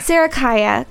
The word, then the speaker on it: Sarikaya: Sarakaya.